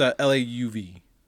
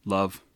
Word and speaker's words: Lauv: No, it's Lauv. – Love.